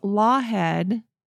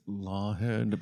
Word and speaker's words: Laughead: Well, in March 1954, a doctor named Charles Lawhead, which is interesting because it is spelled Laughead. – Laughead. – L-A-U-G-H. So like laugh. – Yeah. – E-A-D. – Okay. – But it's, what did I say? Lawhead. – It's Lawhead, please.